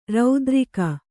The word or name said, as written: ರತಿರಾಗಿ